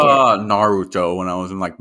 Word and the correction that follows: Naruto: You said that in 4.5 syllables instead of just saying Naruto.